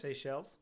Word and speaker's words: Seychelles: A few other African countries also recorded fatalities, one in Kenya, three in Seychelles. Seychelles? Yeah, there you go. I practiced it. I did not say it correctly.